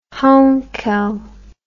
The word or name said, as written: heinkel